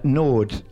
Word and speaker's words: Naude: Nord. N-A-U-D-E,